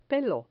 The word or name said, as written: pelo